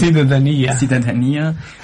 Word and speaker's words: Cidadania: Was haben die Architekturstudenten bei der Projekt Cidadania, oder wie spricht man das? – Cidadania, über dieses Thema geleistet.